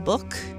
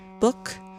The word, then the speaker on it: Buch: It's from guitarist ML Book. I think that's how you pronounce her name. B-U-C-H. Book.